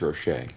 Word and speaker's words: Roche: Is it Roche or Roche? – Well, since they're all speaking French, I'd say it's Roche.